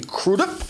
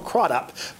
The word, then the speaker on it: Crudup: Of course, she married Billy Crudup. That's apparently how you say it. Not Crudup.